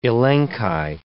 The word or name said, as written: elenchi